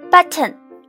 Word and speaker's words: button: Let me 可以读作 Let me，Let me button b u t t o n button，它作为名词表示扣子，在这里是动词。意思是系上扣子。英美人士经常将两个 t 发成鼻音，读成 button，button，这也是可以的哦。